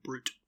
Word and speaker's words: Brut: The Carpenter Brut album? – Excuse you, it is Carpenter Brute. But yes. – Okay, well, you've pronounced it as Brut for the last 5 years, so excuse my ignorance.